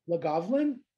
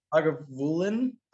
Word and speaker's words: Lagavulin: And that's like, that's the Lagavulin? Is that how you say it? – Lagavulin? – Lagavulin.